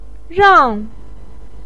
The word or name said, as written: rang4